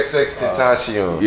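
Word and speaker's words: XXXTentacion: So we all know I can't even say his name, but XX Teenage Sean, or what's how do you say his name? – XX Tentacion.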